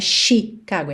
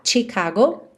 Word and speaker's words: Chicago: Chicago, atenção, a pronúncia dessa palavra tem um tch, não é Chicago, é chi, ok? Tipo tchau, tchau, TCH, Chicago.